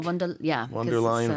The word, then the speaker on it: von der Leyen: Is her name pronounced Lion? – Ursula von der Leyen, I think? – Von der Leyen, von der Leyen.